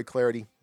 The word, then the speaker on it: Clardy: But don't forget to follow me on Twitter at Troy Clardy. Last name is spelled C-L-A-R-D-Y at Troy Clardy.